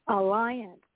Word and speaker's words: Alliant: That's how you pronounce it, Pastor T. Alliant. International Alliant. University. – And Alliant. – Alliant. A-L-L-I-A-N-T, Alliant.